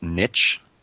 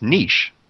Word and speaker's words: niche: I know we're in the middle of the questions here, but I will say, Jamie, I was disappointed in how you pronounced niche. Come on, man. It's niche.